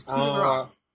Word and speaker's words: coup de grace: You said it wrong, man. What are you doing here? Come on, it's coup de grace.